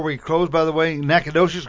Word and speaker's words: Nacogdoches: Nacogdoches.